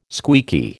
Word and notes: squeaky: American English

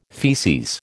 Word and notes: feces: American English

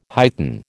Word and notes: heighten: American English

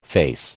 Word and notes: face: American English

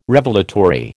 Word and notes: Revelatory: American English

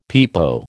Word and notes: pepo: American English

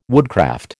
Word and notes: woodcraft: American English

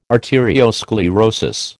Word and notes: arteriosclerosis: American English

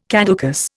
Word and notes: caducous: Indian

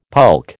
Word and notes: pulque: American English

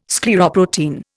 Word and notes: scleroprotein: Indian

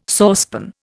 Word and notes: saucepan: Indian

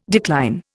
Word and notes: decline: Indian

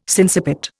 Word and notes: sinciput: Indian

